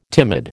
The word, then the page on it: timid: American English